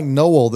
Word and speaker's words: Noel: Man, you know, with a name like Noel that's spelled N-O-E-L, oh, yeah.